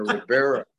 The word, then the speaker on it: Rivera: Did I say that right? – Yeah, you got it, man. Rivera.